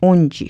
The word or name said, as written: wunji